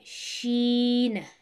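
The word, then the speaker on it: Sheen (ش): but the letter Sheen has three dots on top, and the pronunciation of Sheen is from the middle part of the tongue.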